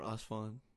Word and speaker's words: Asfand: Hey guys, my name is Asfand, or Asfand. – If you're American. – Yeah, if you can't pronounce Asfand.